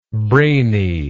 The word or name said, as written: braininess